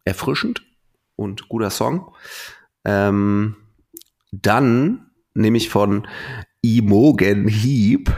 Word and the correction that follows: Imogen Heap: Imogen, glaube ich, heißt es. Imogen Heap.